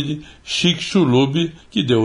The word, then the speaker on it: Chicxulub: Pronuncia-se assim, Chicxulub e